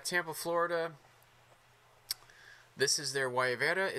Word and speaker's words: Guayabera: I said it wrong in the intro of the show. I said Guayabera. Very white boy of me. – Mm. I thought it was like guava something. So no, I completely messed it up. – It's Guayabera. That's how you pronounce it.